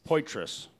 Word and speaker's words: Poitras: I don't know how to pronounce her last name. Poitras? – Poitras. – Poitras. – Poitras. – Okay. Wait, there was an R in there? – Yeah. P-O-I-T-R-A-S.